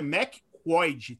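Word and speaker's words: McQuoid: Que ele foi dirigido pelo por, por um estreante que é o Simon McCoy, não sei como se escreve, fala é McCoy, tá ligado?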